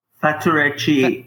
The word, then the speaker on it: Faturechi: Is it Faturechi? Is that how you say your last name? – Faturechi. Yeah.